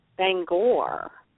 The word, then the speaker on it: Bangor: From Bangor, right? – Yes, Bangor.